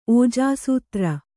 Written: ಓಜಾಸೂತ್ರ

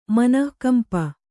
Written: ಮನಃಕಂಪ

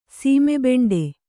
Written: ಸೀಮೆಬೆಂಡೆ

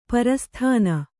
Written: ಪ್ರಸಾಧನಿ

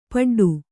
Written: ಪಿಡ್ಡ

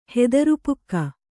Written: ಹೆದರುಪುಕ್ಕ

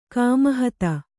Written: ಕಾಮಹತೆ

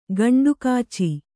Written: ಗಂಟುಕಾಚಿ

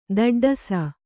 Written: ತಾಟಾಡಿಸು

